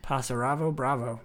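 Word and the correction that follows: Pássaro Bravo: Pasaro Bravo. You added six syllables to that first sentence.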